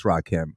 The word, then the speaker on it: Rakim: I know it's Rakim.